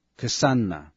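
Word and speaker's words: kṣaṇa: 刹那梵文读作 kshana，一刹那是表示极短的时间，劫梵文是 kalpa，是代表极长的时间。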